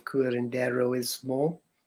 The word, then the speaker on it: Curanderismo: So you're a teacher of Curanderismo, and I know I don't say that right, of the Texas School of Curanderismo.